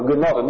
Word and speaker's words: gnosis: Su nombre se toma de la palabra gnosis, con G, pero no pronuncien la G, no digan gnosis o gnosis, no. Gnosis, así se pronuncia, y significa sabiduría, y decían ellos, nosotros sabemos las cosas profundas de Dios.